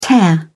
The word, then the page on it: tear off: British English